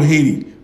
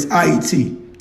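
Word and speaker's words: Hayti: What you guys call Haiti, but it's Haiti. That's the correct pronunciation. I-E-T. H-A-Y-T-I. Not H-A double accent I-T-I. No, that's the French version.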